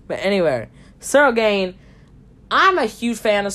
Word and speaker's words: Ciryl Gane: Man, as a huge fan of, uh, why did I say Circle Gain? I meant Ciryl Gane.